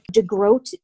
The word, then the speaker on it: DeGroat: And is that, how do you pronounce that last name? – DeGroat. Capital D and then E and then G-R-O-A-T, Chuck DeGroat.